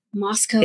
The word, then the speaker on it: Moscow: Moscow. – It's spelled like Moscow, okay. The city in Russia, but it's pronounced differently.